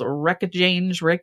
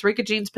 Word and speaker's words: Reykjanes: Now, I'm going to say this wrong because it's an Icelandic peninsula, but I think it's called Reykjanes. – No, it's Reykjanes Peninsula of Iceland has a large plot of land up for sale, guys, with an active volcano.